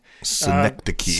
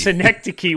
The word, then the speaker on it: Synecdoche: Synecdoche. – Synecdoche.